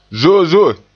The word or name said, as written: zozo'e